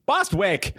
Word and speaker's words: Bostwick: The only two actors I knew, Simon Helberg and Barry Bostich. – Bostwick. – Bostwick, yes. – It was his birthday the other day. And you said his name wrong.